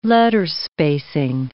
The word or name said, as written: letterspacing